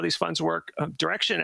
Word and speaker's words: Direxion: Just know that even though it's pronounced Direction, it's spelled D-I-R-E-X-I-O-N. So you might want to say Direction, but that's not how they pronounce it.